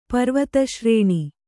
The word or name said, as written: ಪರ್ವತಶ್ರೇಣಿ